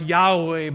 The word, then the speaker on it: Yahweh: In the Hebrew, that's the name Yahweh. Or in older times, they thought it might be pronounced Jehovah. We see that in the King James and hymns. Most scholars today believe it's, it was pronounced Yahweh,